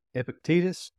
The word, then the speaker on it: Epictetus: It's from, I can never pronounce his name right, Epictetus. Does that sound right? – The Greek philosopher? – Yeah. – Yes. I wouldn't say for sure I knew how to pronounce it either. – Yeah, I always mess it up. But and it's not Hepatitis, so.